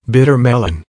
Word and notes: bitter melon: American English

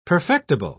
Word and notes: perfectible: British English

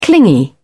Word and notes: clingy: British English

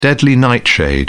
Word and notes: deadly nightshade: British English

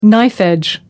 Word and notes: knife-edge: American English

